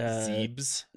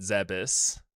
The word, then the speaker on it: Zebes: God, uh, now I gotta look this up because I've always pronounced it uh, Zebes. Zebes. Everyone I heard talk about it called it Zebis. Okay, then we'll go with it. I it could be Zebes, it could be Zebis.